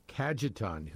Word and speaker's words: Cajetan: Today, the church celebrates the feast of Saint, and you may have to help me out on pronunciation, Cajetan? Cajetan. – Cajetan.